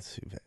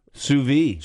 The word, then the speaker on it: sous-vide: Sous being French sous-vide. – For under, and vide being French for vacuum. – There you go. – Sous-vide.